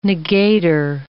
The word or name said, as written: negator